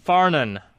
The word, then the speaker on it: Farnan: I'm going to give you the correct pronunciation. – Please. – It's Farnan. – Farnan. Thank you. – It's an Irish name.